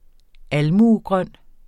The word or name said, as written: almuegrøn